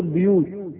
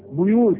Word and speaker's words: بيوت: ان ضممنا الباء بيوت صحيح، وان كسرنا الباء بيوت يبقى برضو ايه لا تعيب على من قرأها كذا.